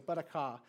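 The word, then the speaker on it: barakah (ברכה): I got critiqued in how I pronounced it in the first service, but it's like barakah.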